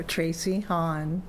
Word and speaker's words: Tracy Honn: Sure, Tracy Hong, T-R-A-C-Y-H-O-N-N.